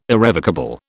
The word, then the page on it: irrevocable: British English